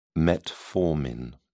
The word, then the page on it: metformin: British English